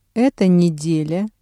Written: эта неделя

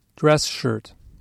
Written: dress shirt

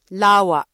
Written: lawa

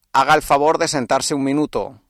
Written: sentarse

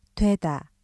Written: (…이/가) 되다

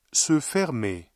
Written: se fermer

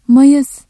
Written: Mayıs

